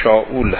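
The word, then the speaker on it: شاول (שאול): دوران پادشاهی تالوت یا شاول به اصطلاح در قرآن کریم تالوت آمده، تلفظ عبریش شاول هست.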